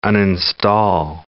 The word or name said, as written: uninstall